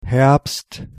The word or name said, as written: fall